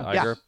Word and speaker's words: Iger: Iger.